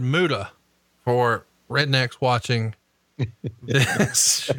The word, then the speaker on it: Muta: I say Muta, M-U-T-A. That is the correct pronunciation and spelling, I have been informed.